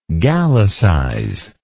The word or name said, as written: Gallicizer